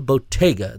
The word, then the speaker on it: Bottega: We are joined by our guest today, the owners of downtown's finest arts and entertainment venue, James and Bridget Neely of Bottle Bottega. That's spelled B-O-T-T-E-G-A, correct? – Correct. – All right. And that's a little different than Bottle Bodega, B-O-D-E-G-A, where you would get a 40 or Mountain Dew.